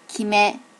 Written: kime

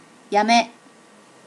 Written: yame

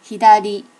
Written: hidari

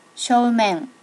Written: shomen

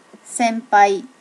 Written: sempai

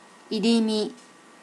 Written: irimi

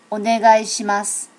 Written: onegaeshimasu

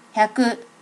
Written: hyaku

